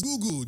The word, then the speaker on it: Google: Pronounce Google as goggle. – So I pronounce goggle as? – Goggle. – Okay. – No, maybe probably. – It's Google. G-O-O-G. Google. G-E-R. – G-E-R.